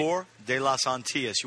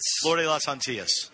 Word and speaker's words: Flor de las Antillas: Flor de las Antillas. You want the A to Antillas. – Antillas. – Flor de las Antillas. – Flor de las Antillas.